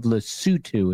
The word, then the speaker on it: Lesotho: Hello, STS Nation from the Kingdom of Lesotho, it is pronounced.